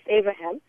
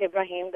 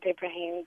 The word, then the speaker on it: Ibrahim: His name is Ibrahim, which is often mispronounced as Abraham. And he always corrects people that it's Ibrahim, the Prophet Ibrahim, the father of all three Abrahamic faiths.